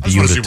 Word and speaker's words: Buda: No, that's how it's pronounced. – Okay. – Well, B-U-D-A? – Can you yes. get someone on the phone from Beuda, Texas, please? – You know, B-U-D-A.